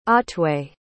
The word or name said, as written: otway